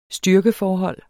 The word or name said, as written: styrkeforhold